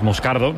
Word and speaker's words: Moscardo: Como bien ha pronunciado Unai, es Moscardo, es brasileño, sí.